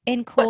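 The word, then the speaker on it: en caul: Or in, what's the pronunciation? – I'm call? Actually in call. – What does it mean? – It's E-N space C-A-U-L.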